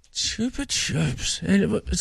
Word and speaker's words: Chupa Chups: how you actually pronounce Chubba Chubs. – Your Chupa Chup pop without this. – Chupa Chups. Spanish, Megan.